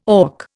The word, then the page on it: auk: Indian